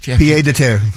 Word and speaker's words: pied-à-terre: That Pied-à-terre.